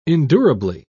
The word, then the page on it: endurably: British English